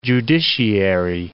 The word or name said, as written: judiciary